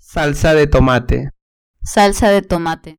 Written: salsa de tomate

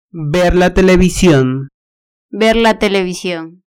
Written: ver la television

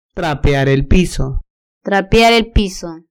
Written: trapear el piso

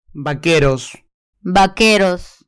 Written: vaqueros

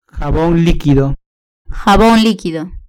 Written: jabon liquido

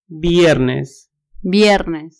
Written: viernes

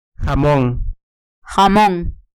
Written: jamon

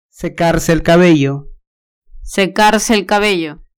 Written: secarse el cabello

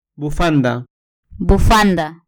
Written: bufanda

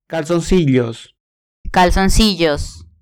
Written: calzoncillos

